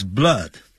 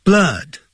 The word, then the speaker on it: blood: When he sees blood, 他看到血液的时候，他就整个就昏倒。 Blood, 不要念成 blood, 肚子饿的饿 ,blood, 饿，像倒 V 状的那个符号要先了解。